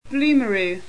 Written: Blumaroo

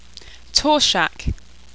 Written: torshac

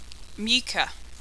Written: Meuka

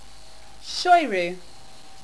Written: Shoyru

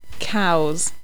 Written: kaus

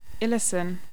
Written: illusen

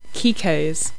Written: kikos

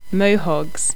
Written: Moehogs

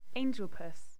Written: angelpuss

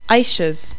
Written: aishas

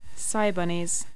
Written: cybunnies

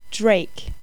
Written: draik

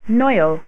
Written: Noil

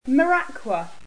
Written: maraqua

